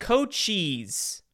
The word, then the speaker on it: Cochise: And then hopefully, oh, excuse me, it's pronounced Cochise. All right, Goat Cheese County.